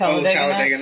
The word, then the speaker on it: Talladega: Talladega Nights, or I don't know how that's pronounced. – Talladega Nights.